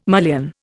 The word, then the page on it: mullion: Indian